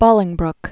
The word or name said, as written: Bolingbroke